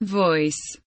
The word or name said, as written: voice